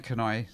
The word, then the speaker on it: Canoise: However, to make sure we knew who really knew the band, we would spell it differently and pronounce it differently than it was spelled, and that's been haunting us to this very day. So we called the band Canoy, spelt it C-A-N-O-I-S-E, and it at that point, it meant nothing.